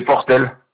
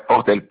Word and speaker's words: Portel: C'est Portel. – Alors, comme ça se prononce? – Ouais, Portel, P-O-R-T-E-L. – Alors, c'est... Votre prénom? – Edilberto, E-D-I-L-B-E-R-T-O.